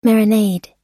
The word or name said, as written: marinade